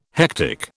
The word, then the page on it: hectic: British English